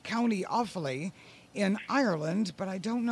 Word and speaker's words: Offaly: What county are you in? – We're in Offaly. – Offaly. Okay, that's... Yes. – O-F-F-A-L-Y.